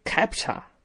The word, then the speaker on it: CAPTCHA: 验证码的英文名是 CAPTCHA，读音应该是 CAPTCHA，这不是一个正规的单词，而是一个缩写。